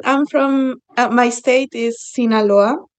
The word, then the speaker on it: Sinaloa: Sinaloa.